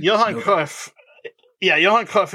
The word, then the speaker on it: Johan Cruyff: "Truly, it was an honor to play here at the Johan Cruyff Arena." – Yeah, Johan Cruyff Arena. Marlein says, "Oh, no, the honor is ours." And then in true Dutch fashion, "ours completely, but it's pronounced Johan Cruyff.